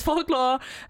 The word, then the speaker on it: Folklore: Es ist so ein bisschen witchy, es ist so ein bisschen verruchter als Folklore. Das war sehr deutsch ausgesprochen. Entschuldigung, als Folklore.